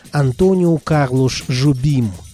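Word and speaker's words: Antônio Carlos Jobim: 25 января 1927 года родился и 8 декабря 1994 года ушел из жизни крупнейший автор бразильской современной музыки Антонио Карлош Жобим. Да-да, это имя в Бразилии произносится именно так.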